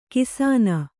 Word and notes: ಘೋಸನೆ: Kannada

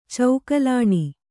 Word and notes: ಚೌಕಲಾಣಿ: caukalāṇi

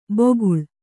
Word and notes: ಬೊಗುಳ್: boguḷ

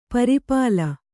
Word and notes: ಪುರ್ಪಾಲಿ: purpāli

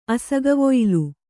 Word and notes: ಅಸಗವೊಯ್ಲು: kannada

